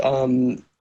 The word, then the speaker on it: Eosono: There's a company called Ionosound, which Eosono, actually, I think is what you're thinking. – I'm sorry, I mispronounced it.